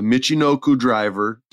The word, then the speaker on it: Michinoku: I don't feel that they booked her well so far, but she did I'm going to say it wrong, a Michinoku driver. Did I say it right? – You said it right, but she's calling it a Piper driver.